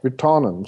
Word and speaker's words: Virtanen: Det ska tydligen uttalas så. Det är så alltså? Ja, Virtanen sa han alltid.